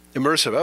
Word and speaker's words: IMRSV: Is there am I missing some sort of clever pronunciation of that? Immersive.